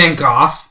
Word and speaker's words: Van Gogh: Van Gogh. Excuse me. – You speak Dutch. It's Gogh.